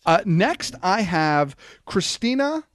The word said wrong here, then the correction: Kristanna: Is it Loken? Loken? – Kristanna Loken. – Is that Kristanna? Is that how it's pronounced? – Kristanna Loken.